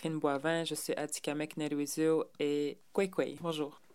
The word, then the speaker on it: Atikamekw: Ben, Nikotwaso, c'est dans ma langue Atikamek. Moi, je suis Atikamek et... Même là, je vais le prononcer comme il se doit, Adikomek.